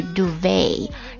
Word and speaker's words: duvet: 对了，那这个羽绒被的单词呢，就是 duvet, D-U-V-E-T, T 是不发音的。